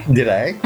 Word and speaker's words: g'day: I feel like such a fraud with my good days, but you're pronouncing the D. It's g'day.